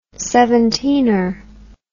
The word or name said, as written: seventeener